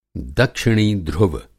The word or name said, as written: दक्षिणी ध्रुव